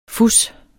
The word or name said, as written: fus